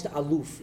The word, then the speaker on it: aluf: And so here we look at this word aleph, or almost aluf is the way it's pronounced.